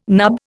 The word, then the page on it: nub: Indian